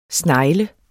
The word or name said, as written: snegl